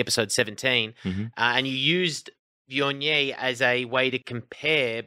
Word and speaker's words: Viognier: Viognier, yeah, that's Viognier.